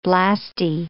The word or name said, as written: blastie